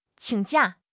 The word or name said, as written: qing3 jia4